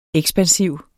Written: ekspansiv

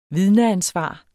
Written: vidneansvar

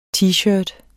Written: t-shirt

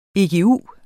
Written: egu

